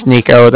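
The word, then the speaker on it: Niko: Like I said, my name's Nico. That's N-I-K-O.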